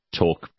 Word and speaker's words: torque: Yeah, but Leanne, you pronounce it talk, but you spell it TORQUE, not TALK.